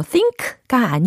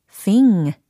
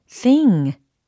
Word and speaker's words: thing: 어, think가 아니에요. 비교를 하자면 thing, thing, thing. 예, 이와 같이 끝소리를 응, 응, 이 사운드를 내주시면 되겠습니다. It's the big thing in the calendar.